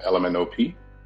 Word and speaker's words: LMNOP: I work as a gallery curator in Baton Rouge, one half of an organization called LMNOP, which is pronounced like the alphabet.